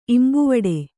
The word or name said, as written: ಇಂಬುವಡೆ